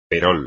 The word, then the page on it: perol: espagnol